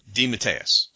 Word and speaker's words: DeMatteis: Yeah, I asked him. I said, how do you pronounce it? And he actually gave me two different variations. So I'm going with DeMatteis.